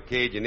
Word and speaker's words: Cajun: but I always like to explain what a Cajun is. Hey! You got it, man. Cajun, generally spelled C-A-J-U-N, pronounced Cajun in Mexico.